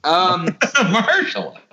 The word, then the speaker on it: Mahershala: Say it with me. Say Mur. – Hersh. – La. – Hershey's Kisses. – Mahershala.